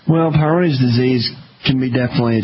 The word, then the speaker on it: Peyronie's: I was diagnosed with a condition called Peyronie's, and okay. I don't know if I'm pronouncing it right. – You are. Peyronie's disease.